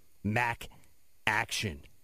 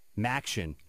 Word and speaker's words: Maction: And as I mentioned to start the show, Mac Action. Maction I should say. That's how you pronounce it. Maction. It's a play on words.